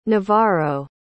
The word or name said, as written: navarro